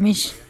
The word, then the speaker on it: Misch: Tom Misk. Es difícil de pronunciar, aunque es muy simple su apellido. Es M-I-S-C-H.